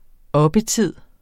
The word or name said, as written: oppetid